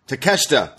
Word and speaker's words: Takeshita: Konosuke Takeshita? – Takeshita.